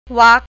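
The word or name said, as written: วรรค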